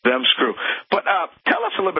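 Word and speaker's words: ZZEM Screw: You're in the South. I'm sure you're comfortable saying Dem Screw, but this is Zem Screw. Z-Z-E-M. – Zem Screw.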